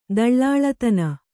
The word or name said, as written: ದಳ್ಳಾಳತನ